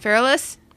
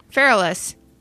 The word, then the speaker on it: Feralis: How do you pronounce that? – Feralis. Feralis. It's like feral. Feralis.